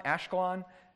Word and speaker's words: Ashkelon: Gaza, Ascalon, Ashkelon, another pronunciation, Ekron, with the coast thereof.